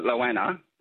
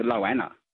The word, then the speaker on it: Lowanna: The locals call it Loana, but when I'm on the phone, they all call it Loana. And it's spelled L-O-W-A-N-N-A. So it's a Loana to me. – Mm, like Goanna.